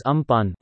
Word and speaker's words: Amphan: In an advisory to West Bengal and Odisha governments, the Union Home Ministry has said Amphan, pronounced as Ampan, was spotted on Monday morning over central parts of South Bay of Bengal and the adjoining Central Bay of Bengal.